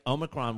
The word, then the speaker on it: Omicron: Like, Omicron was, I think that's how you say it.